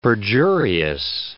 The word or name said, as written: perjurious